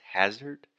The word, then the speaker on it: hazard: So that first sound is the ah, just like dad, master, disaster. So the correct pronunciation is hazard.